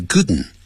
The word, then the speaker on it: couldn't: But the battery died, 千万不要让人死了这叫没电。这个电池没电了这样子。好千万这里不要当死这个字。die 这个字呢除了当死以外因为还有别的意思这样电池没电。或者你可以用形容词 the battery, 或是 the battery,is dead, D-E-A-D 好我们也可以用 dead 这个形容词 and he couldn't make the call 所以他就没办法打通这个电话 couldn't 不要念成 couldn't you didn't 的时候我们常常跟他讲用鼻音出来 couldn't